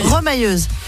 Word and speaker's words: remailleuse: Remailleuse. – Ça se prononce pas comme ça? Remailleuse? – Remailleuse, oui. – Ah, remailleuse, pardon.